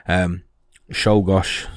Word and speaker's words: Czolgosz: Now, I don't know if I've pronounced that right. I have found a few things online. Czolgosz seems to be the preferred pronunciation, or the pronunciation that people go with. So that's what we're going with.